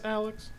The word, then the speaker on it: Alyx: Is it pronounced Alyx? – Yeah. – Alyx! – Oh, okay. – That's her name. The character's name is Alyx, yeah, but it's with a Y, yeah.